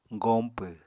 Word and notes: goñpw: apatani

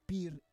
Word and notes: pir: Roon

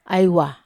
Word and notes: ai wa: Wamesa

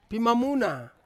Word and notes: pimamuna: Ansus

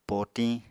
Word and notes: potiñ: apatani

